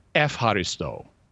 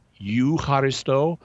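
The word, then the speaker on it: efharisto (ευχαριστώ): So to give an example, the word in first century Greek, eucharisto, means thanksgiving, and it's where we get Eucharist, and you would pronounce it eucharisto. But in modern Greek, it's used as a greeting. It means hello, and it's pronounced efharisto. And how you get efharisto from eucharisto, I am not sure, but that's what they do in modern Greek.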